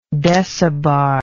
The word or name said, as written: decibar